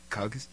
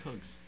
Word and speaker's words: Qugz: I really don't know how to pronounce it. – Q-U-G-Z. – Yeah. Yeah. Well, how do you pronounce that? – Kugs? Just Kugs.